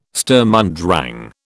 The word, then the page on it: Sturm und Drang: British English